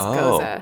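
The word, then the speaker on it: gose: It's G-O-S-E, and it's pronounced goza. – Oh, goza.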